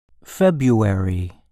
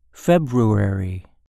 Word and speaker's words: February: For instance, I grew up in New England, where we pronounced the R in February and the T in often.